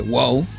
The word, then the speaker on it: WOW: W-O-W, WOW, no sé cómo se pronuncia, WOW.